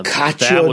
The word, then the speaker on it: cacio: Oh, cacio di pepe. Yes. – Yeah, cacio. Yeah, I can never pronounce it. But I made that. – Two C's together, generally a CH.